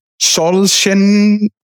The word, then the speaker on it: Solzhenitsyn: Solzhenitsyn.